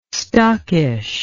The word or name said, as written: stockish